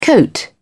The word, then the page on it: coat: British English